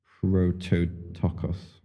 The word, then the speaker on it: prototokos: Well, the term firstborn, which in the Greek is pronounced prototokos. Yeah, that's right. We haven't done Greek in Bible college yet, so you have to forgive me.